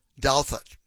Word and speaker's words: Douthat: I found the correspondent, his name, he pronounces it Ross Douthat. Ross Douthat, like Dow Jones. He said, like the Dow in Dow Jones, thought Douthat.